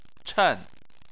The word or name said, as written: chen4